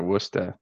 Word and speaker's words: Worcester: So yeah, my stepdad's from Worcester, just right out. Yeah, Worcester, Massachusetts. So it's funny. – You said it right though.